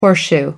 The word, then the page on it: horseshoe: American English